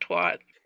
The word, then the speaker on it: twat: What is the American pronunciation of twat? Is twat, twadder, or twatter? – Twat. – Twat. – Twat. Twat. – Even though it's an A and not an O.